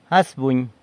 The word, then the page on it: As, buny: sanlucasquiavini